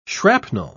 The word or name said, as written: shrapnel